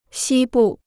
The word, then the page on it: 西部: xī bù